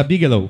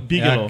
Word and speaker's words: Bigelow: os dois últimos filmes de Bigel, eu não sei pronunciar isso. Da Bigelow. Bigelow.